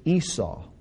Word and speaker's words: Esau: Well, Esav, we pronounce it Esau.